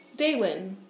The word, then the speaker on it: Daylen: Daylin. – Can you spell that for me, please? – Yes. D-A-Y-L-E-N.